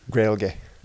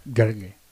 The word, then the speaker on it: Gaeilge: There is no R in the Irish word for Irish, but it's pronounced with one. – Nice. I don't know. Yeah, what is the Irish word for Irish? Or is it just Irish? – It's Gaeilge. – Gaeilge.